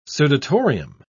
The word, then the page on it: sudatorium: British English